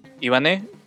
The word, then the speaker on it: Ivané: Ivané, que lleva acento en la...